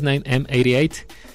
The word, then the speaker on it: 9M88: Por eso lo pronunciamos en inglés, 9M88.